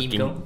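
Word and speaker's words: Kymco: Jo, ale je to Kimko.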